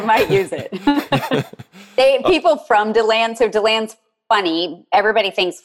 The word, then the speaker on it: DeLand: How do you say it? DeLand or DeLand? – DeLand. – DeLand. Yeah. So if somebody comes to town and they say DeLand, you know they're from out of town.